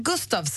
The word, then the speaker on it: Gustavs: Bra, du säger från Gustavs, säger Anders. Är det helt rätt uttal? Det är det, va? – Jajamän, det stämmer bra det.